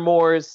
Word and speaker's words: Moores: Mr. Moores sounds like s'mores.